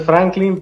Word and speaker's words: Franklin: Bueno, este, hablando de mí, eh, mi nombre es Franklin, pero como era impronunciable para muchos amiguitos en la escuela, me decían Cranky.